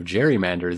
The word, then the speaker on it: gerrymander: Peter writes in, trying to correct my pronunciation of gerrymander, though I said it properly. You see, the word came from a man whose last name was Jerry with a soft G, and so gerrymander holds that.